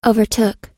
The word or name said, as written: overtook